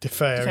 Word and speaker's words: DeFeo: Is it DeFeo? Is that how you pronounce it? Am I understanding that right? – DeFeo. Yeah.